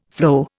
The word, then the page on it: floe: Indian